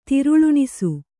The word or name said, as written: ತಿರುಳುಣಿಸು